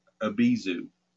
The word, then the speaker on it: Abyzou: In other texts of the period, Hellenistic and the Testament of Solomon, it's the same entity, and she's called Obizoth, O-B-I-Z-O-T-H, or Abizu. And you can spell that A-B-Y-Z-O-U or A-B-I-Z-U.